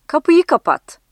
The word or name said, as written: kapat-